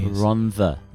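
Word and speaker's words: Rhondda: No, there's no D. The double D is pronounced as a TH. – Double D. – Rhonda.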